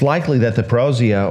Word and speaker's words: parousia: Now, all three parables deal with the behavior of people as they anticipate the parousia. Is that how you pronounce that, you pastors? What's that, Jeff? Parousia? All right, thank you.